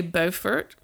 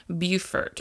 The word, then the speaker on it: Beaufort: So you can either say Beaufort, which I think is what South Carolinas say, or the North Carolinas pronounces it Beaufort. – Mm-hmm. – So two different ways of saying it, depending on where you're from or who your people are.